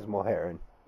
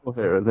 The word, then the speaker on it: Mulheron: Justin Willis beat James, I'm going to butcher his last name, Mulheron, something like that. – James Mulheron. – Mulheron, there we go.